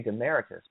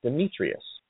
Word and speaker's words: Demetrius: I keep wanting to say Demaricus, but that's not right. It's Demetrius.